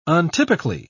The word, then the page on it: untypically: British English